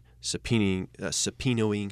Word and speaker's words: subpoenaing: But behind the scenes, continue to work extremely aggressively investigating, subpoenaing, how do you say that word? – Subpoenaing.